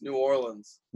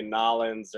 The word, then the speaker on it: New Orleans: So if you want to sound like you're from here, don't say Nollins or New Orleans. It's New Orleans.